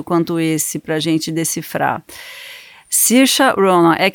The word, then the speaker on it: Saoirse: Por isso que é S-A-O-I-R-S-E é Sirsha, Se pronuncia Sirsha.